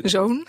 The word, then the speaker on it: ZOWN: Heb je nog, hoe spreek je dat uit? Z-O-W-N. Zo, zoon. – Zoon.